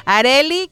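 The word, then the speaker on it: Arelly: Arely con doble L, así se escribe mi nombre, Arely Alcocer, locutora y terapeuta.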